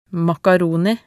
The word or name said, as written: makaroni